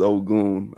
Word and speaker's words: Ogun: The pronunciation is Ogun. I know a lot of people. Yeah, Ogun. A lot of people mix it up. Yeah, because I mean it has the word you know, gun in it, so a lot of people pronounce it like gun or you know, whatever pronunciation that comes out of their mouth first. But so it's Ogun.